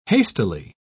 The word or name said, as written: hastily